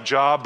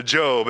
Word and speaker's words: Job: It reads like Job, but it's pronounced uh, Job.